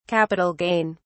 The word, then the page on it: Capital gain: British English